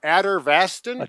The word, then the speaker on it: Atorvastatin: Atorvastatin. – Atorvastatin.